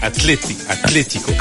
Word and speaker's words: Atlético: L'accent sur le. C'est Atlético. – Ah oui, non, les accents, j'ai vite abandonné, moi. – Ouais, c'est compliqué, hein, mais voilà. C'est ce qu'on appelle l'accent tonique et c'est sur le. Là, c'est accentué sur la deuxième saison.